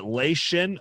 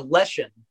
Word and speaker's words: Leshen: Is it Lation? I think of it as like a Mortal Kombat character when I read it for some reason. – It's a Leshen.